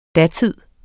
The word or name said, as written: DATIDENS